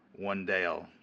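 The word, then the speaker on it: Wan'Dale: It's not Wayne. It's Wendale. Sorry. W-A-N apostrophe D-A-L-E.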